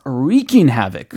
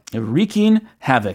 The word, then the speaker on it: wreaking havoc: 예, 그래서 어, wreak havoc 라고 발음을 해야 되는 거죠. – wreaking havoc. – 예, wreaking havoc.